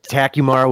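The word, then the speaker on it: Takumar: That was actually you said Tekumar with perfect Midwestern diction, Mike. Yeah. That is absolutely the correct way to say that word.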